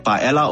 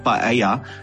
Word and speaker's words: paella: So, paella is 'n traditionele Spaanse dis, en die Spanjaarde spreek nie die dubbel al uit, soos ons altyd doen nie, maar die Engelse doen wel.